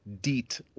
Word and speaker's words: Diet: Her name is Deet. You spell her name like the English word diet, but you pronounce it Deet, like the mosquito repellent.